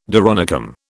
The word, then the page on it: doronicum: British English